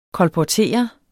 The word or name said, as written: kolportere